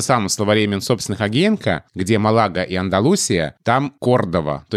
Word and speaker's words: Малага: Ну и Малага, да, то же самое. Правильно, Малага. И я надеюсь, что в русских словарях будет Малага тоже.